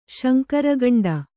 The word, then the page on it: ಶಂಕರಗಂಡ: śankara gaṇḍa